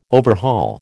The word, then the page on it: overhaul: American English